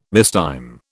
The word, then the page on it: mistime: British English